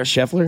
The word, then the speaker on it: Scheffler: I like Adam Scheffley, too. – Yeah. – Which one did I butcher there? – Scheffler? – Scheffler.